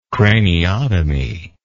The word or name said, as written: craniotomy